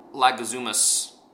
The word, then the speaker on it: Leguizamo: Like, first of all, John Leguizamo's...